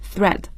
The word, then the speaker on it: thread: 嗯，这个 thread 是注意一下它的发音 th 这样的声音 thread。